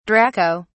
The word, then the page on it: draco: British English